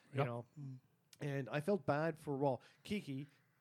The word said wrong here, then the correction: Kiwi: Sorry, I keep saying his name wrong. – Kiwi.